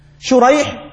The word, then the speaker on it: شريح: شريح وسريج، شريح بالمعجمة وسريج بالمهملة، السين هنا مهملة هناك الشين المعجمة، وهذا مصغر وهذا مصغر، وهذا هو الذي أحدث الاشتباه كما نص على ذلك العلماء.